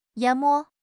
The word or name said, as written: 淹没